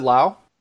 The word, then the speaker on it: Lowe: Is it Lau? – It rhymes with Wow. – Oh, you betcha. I had no idea. Brandon yeah. Wow. – So so many puns, but yeah, it's Brandon Lau.